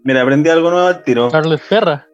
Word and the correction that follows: Perrault: Perrault. ah, ya. Así parece que se pronuncia.